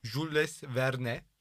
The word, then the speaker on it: Jules Verne: Ben öyle okurum abi isimleri. Bana ne? Jules Verne diye okunuyorsa bana ne yani? – Niye Jules Verne okumuyorsun o zaman? Kaytarma şimdi. – Jules dedim işte. – Ha, Jules diyeceksin o zaman. – Ama J diye okunur Türkçe.